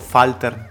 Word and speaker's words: Walter: Io ci darei un colpetto un po' di tedesco, Falter con un po' di eh, F. Eh, così, no, no? – tedesco la W si pronuncia uh, V.